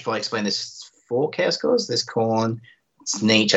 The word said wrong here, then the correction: Slaanesh: I never say that right, Slaanesh, and Nurgle.